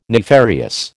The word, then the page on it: nefarious: American English